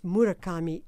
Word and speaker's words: Murakami: His name is spelled H-A-R-U-K-I, Haruki. And his surname is Murakami, M-U-R-A-K-A-M-I, Murakami.